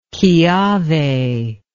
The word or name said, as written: kiawe